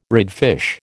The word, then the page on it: redfish: American English